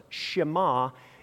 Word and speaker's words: Shema: It's called the Shema because the Hebrew word or the first word of this prayer is listen or hear, but it's pronounced Shema in Hebrew.